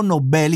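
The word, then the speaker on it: Nobel: Μα ακούτε που λέω Νόμπελ γιατί έτσι προφέρεται στα σουηδικά ε, ο Νόμπελ και το επίθετο αυτό και βεβαίω το βραβείο.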